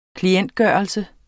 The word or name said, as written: klientgørelse